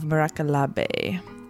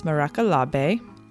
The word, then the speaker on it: Miracolabe: And this week's guest is Vanya Vukalik of Miracolabe. And you may have come across her on Instagram, and maybe you think it's pronounced Miracolab, but it's Miracolabe.